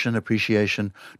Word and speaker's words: appreciation: Because, again, the dictionaries in Australia say the preferred form is a sh. And this is because s and e, c or s, it's called palatalization, and you move the pronunciation of the s back a little bit in the mouth and you make it into a sh. So appreciation.